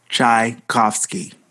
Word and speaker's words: Tchaikovsky: Tchaikovsky.